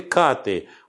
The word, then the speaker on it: каты: И понимая, что в слове «каты» ударение делается на букве «а», говорил мне, искажая это слово, про наиболее убедительного исполнителя этих самых кат.